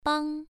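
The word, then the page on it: bang: tone 1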